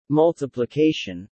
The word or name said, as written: multiplication